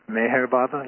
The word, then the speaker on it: Meher Baba: I don't know if I pronounced that right. – It's Meher Baba.